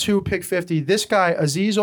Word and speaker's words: Azeez: Azeez Ojulari, look up what happened to him at the draft, please.